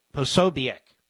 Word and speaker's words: Posobiec: How do you pronounce that guy's name? Posobiec.